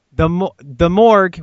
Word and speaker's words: Gmork: The Morgue, which is spelled G-M-O-R-K, even mm-hmm. though it sounds like the morgue when you hear it in the movie, is still the scariest creature on the planet.